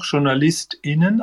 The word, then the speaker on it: JournalistInnen: Also mein Plädoyer wäre für das Binnen, das große Binnen-I, weil so spricht man es ja auch, JournalistInnen, also so ein bisschen betont. Man macht ja keine Riesenlücke, sondern man betont sozusagen das Innen.